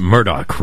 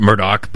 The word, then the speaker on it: Murdaugh: They pronounced it Murdaugh, right, down there? – Yeah. – The family pronounced it Murdaugh, but everybody else was Murdaugh.